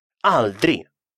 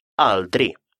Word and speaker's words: aldrig: Aldri. Никогда. Aldri. Обратите внимание, что финальное «ге» в этом слове не читается.